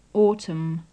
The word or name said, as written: autumm